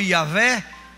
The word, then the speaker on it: Iavé: conhecido como Iavé, pronunciado por nós em português como Jeová.